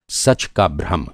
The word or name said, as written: भ्रम